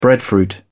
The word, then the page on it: breadfruit: British English